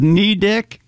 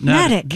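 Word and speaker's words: Neddick: It rhymes with medic, according to this. – Natick. – Natick, then, not Knee Dick. – It sits on the small Nubble Island. – What? – Nubble. N-U-B-B-L-E.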